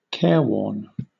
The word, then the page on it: careworn: British English